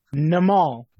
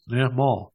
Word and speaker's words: Nimal: Nimal. – Two syllables. Nimal.